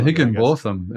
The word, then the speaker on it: Higginbotham: how dare you suggest it's pronounced Higginbotham? It's like the Higginbothams and Higginbottoms have been feuding centuries.